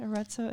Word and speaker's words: Arezzo: Arezzo, Italy? – Right.